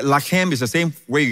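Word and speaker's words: lechem (לֶחֶם): And by the way, the word bread in Hebrew is lechem. And the word fight is lacham. Literally, from the same words, it's literally spelled the same way. Lechem, lacham. And it's just pronounced differently.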